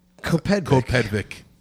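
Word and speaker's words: CoPedvic: I'm saying GoPedvic. It's spelled capital C, lowercase O, capital P, E D V I C. – GoPedvic. Yeah, that sounds about right.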